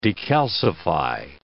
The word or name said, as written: decalcify